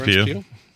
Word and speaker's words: Pugh: Florence Pugh. The only reason I know how to pronounce her name now is because she does a thing where she says, you know, like pew.